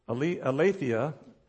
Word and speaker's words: aletheia: That's the Greek word, aletheia, is how you pronounce it.